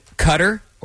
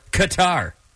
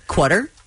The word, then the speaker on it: Qatar: Qatar is the only country that starts with the letter Q, and you can pronounce it Cutter or Qatar. – Oh. They both are acceptable, huh? – Mm-hmm. – Can you call it Quarter? – No. Astronauts who go to the International Space Station need to become fluent in both English and Russian before they can go.